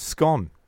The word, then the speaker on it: scone: The other big question, of course, before we move on to talk about the actual football, is how you say the word scone. – Scone. – Scone or scone.